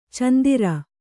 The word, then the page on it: ಚಂದಿರ: candira